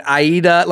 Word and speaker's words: Aida: And because it's spelled A-I-D-A. – Right. – And I was like, is it Ida or is it Aida?